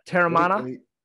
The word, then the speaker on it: Teremana: Uh, Teremana.